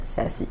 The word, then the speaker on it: Saci: Et pour les intimes, c'est Myriam Sassi. parce que c'est pas tout le monde qui le prononce ici, donc je dis.